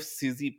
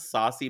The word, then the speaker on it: Sosie: You like clearly had never heard it out loud, and it's spelt like Susie one five XL plus dot com. She she oh, spells it really like celebrity baby name. And you were like trying to pronounce it, and one of the ways you pronounced it was saucy bacon.